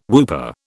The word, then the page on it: whooper: British English